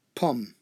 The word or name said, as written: pomme